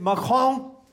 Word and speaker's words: Macron: Folk er rasende på præsident Macron. Folk er... Det skal udtales sådan, det gider jeg ikke diskutere.